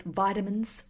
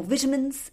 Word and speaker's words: vitamins: They're all about helping you with vitamins or vitamins, if you're in the UK. I always get told off for saying it incorrectly. Vitamins, as we would say in Australia.